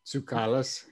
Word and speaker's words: Tsoukalos: Yeah, I think the emphasis should be on the K-A is where the accent would be if it was in Greek, but it would be like Tsoukalos, so, but I don't know.